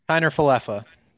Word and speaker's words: Kiner-Falefa: Isaiah Kiner-Falefa. – That's pretty close. – Is it Keener or Kiner? – Kiner-Falefa.